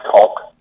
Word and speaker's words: Keogh: Is it Keogh or Co? One of the two. It's K-E-O-G-H. So he was also the inventor of the 14-1 game as we know it today. Also can be contributed to an Irishman. What do you know about that? – I believe it's pronounced Cog. – Cog? Cog, maybe? – Yeah, Cog. There's like a K at the end, it's Cog.